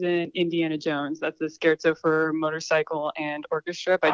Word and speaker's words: scherzo: Everyone in the class is so I said scherzo, so I'm sure you're right. Yeah, sounds like Cher and Alonzo Mourning or something.